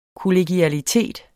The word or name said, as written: kollegialitet